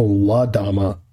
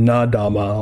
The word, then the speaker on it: La Dama: How do you spell that? – L-A-D-A-M-A. – Oh, La Dama. – La Dama, yeah. – I was hearing Na Dama. I was like, what? – Yeah, La Dama.